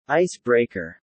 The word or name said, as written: ice breaker